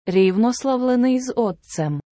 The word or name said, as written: рівнославлений з Отцем